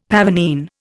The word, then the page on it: pavonine: Indian